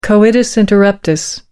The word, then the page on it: coitus interruptus: American English